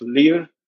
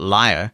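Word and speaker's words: Lire: It's a verb, which is, I believe it's pronounced lear. And it's the meaning is to read. – So I've been pronouncing it wrong all this time, you mean? – Well, I think it's because VoiceOver pronounces it wrong. – Well, it depends on the TTS, actually. I've had some VoiceOver voices pronounce it liar.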